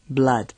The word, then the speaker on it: blood: Pues en este caso la respuesta correcta sería la de blood, sangre, ya que aunque contiene la misma grafía, la doble O, no se pronuncia con el sonido de U larga de todas las demás palabras.